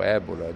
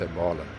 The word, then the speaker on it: Ebola: Veja que coisa extraordinária aconteceu na África com o vírus Ebola ou Ébola, dependendo da pronúncia que nós desejarmos dar. A comunidade internacional bancou economicamente, a comunidade científica se dedicou e hoje a África está livre do vírus Ebola.